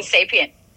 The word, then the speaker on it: Sapient: Sapient.